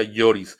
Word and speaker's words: Lloris: Ahí está Loris, por supuesto, eh, gran portero. ¿Con quién juega? Eh, ah, pues con el Tottenham Producer, el Tottenham en, en Europa en la liga Lloris, perdón, se pronuncia Lloris.